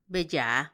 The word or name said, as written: beyaa